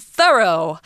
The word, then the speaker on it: thorough: And then we have the word thorough, which is such a great word because it looks like through, it looks like though, and it's not pronounced like either.